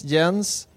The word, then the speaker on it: Jens: It's Jens. It's my name. – J-E-N-S. – J-E-N-S. M-E-D. J. It's like Jens with a J. That's what it's in Swedish.